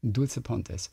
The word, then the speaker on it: Dulce Pontes: Dulce Pontes.